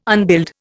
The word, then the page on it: unbuild: Indian